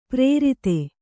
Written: ಪರಾರ್ಧ

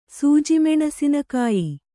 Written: ಸೂಜಿಮೆಣಸಿನಕಾಯಿ

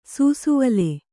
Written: ಸೂಸುವಲೆ

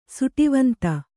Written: ಸುಟಿವಂತ

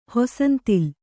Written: ಹೊಸಂತಿಲು

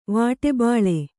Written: ವಾಟೆಬಾಳೆ

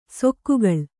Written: ಸೆಕ್ಕೆಗಳೆ

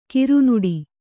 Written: ಕರ್ನಾಟ

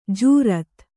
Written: ಜೋರತ್ತು

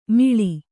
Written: ಮಿಳಿ

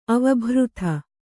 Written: ಅವಭೃಥ

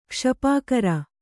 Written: ಕ್ಷಪಾಕರ

